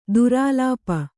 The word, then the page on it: ದುರಾಲಾಪ: durālāpa